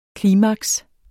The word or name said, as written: klimaks